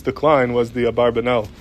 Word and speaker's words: Abarbanel: So machleg is how to say his name, if it's Abarbanel or Avravanel, is that the nusach? And I'm going to go with Minhag Olam as Abarbanel, but they, that's a debate how to pronounce it.